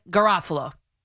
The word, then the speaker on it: Garofalo: That from Ryan Dunleavy, backed by Mike Garofalo on the NFL Network. That's how you say it in Italian.